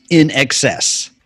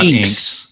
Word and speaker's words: INXS: So, for those who are not familiar with this band, it is actually pronounced INXS. – Right. – It's not Inks. – No. – Nothing screamed you were out of touch more in the 80s than calling these guys Inks.